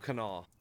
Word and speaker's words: Kanawha: Did I say that correctly? – Yeah. – Okay. – Yeah, you got, if you want to sound real slick, if you ever go to Appalachia, you can just say Old Kanawha.